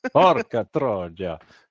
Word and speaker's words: Porca Troia: how do you pronounce Porca Troia? – Troia. – Just roll the R, that's important. – Porca Troia.